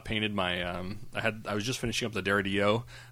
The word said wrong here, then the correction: Dorito: dread. – Oh, the Dorito dread. – Yeah. – Sorry, you mispronounced it there.